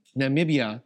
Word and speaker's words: Namibia: Namibia.